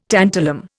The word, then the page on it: tantalum: Indian